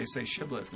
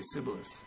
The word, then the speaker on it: Shibboleth: Yeah, that's why we think, you know, the Ephraimites were descendants of Britain, because they couldn't pronounce their H's. So they would say Shibboleth, and they would say Sibboleth.